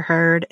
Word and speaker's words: Heard: Now, speaking of celebrity things and lives, you've been doing stories about the Amber Heard and, is that how you say her name? – Mm-hmm.